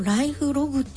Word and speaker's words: 記録: 暮らしの記録と書いて暮らしのライフログって読むんですね。